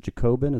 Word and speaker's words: Jacobin: There was a good piece at the is it Jacobin? Is that how you pronounce that? – Sure.